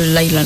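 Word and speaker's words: Lyla: Pero se escribe Lila, pero sí lo pronuncia como un poquito Laila, ¿no? – Sí, es que va con Y. Laila.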